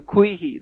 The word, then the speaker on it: Quihi: They call it Quishi, it looks like, Q-U-I-C-H-I. Well, our own Alsatian and German immigrants came here, and they changed the pronunciation then to Quihi. They took the C out and just left a Q-U-I-H-I.